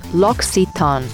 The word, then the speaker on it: L'Occitane: So it is pronounced L'Occitane.